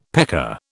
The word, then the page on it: pecker: British English